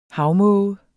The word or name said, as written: havmåge